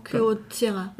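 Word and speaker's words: Kyocera: Gibt, äh, bekanntes Beispiel ist die, ähm, der Druckerhersteller Kyrocera. – Mhm. Habe ich es richtig ausgesprochen? – Kyrocera.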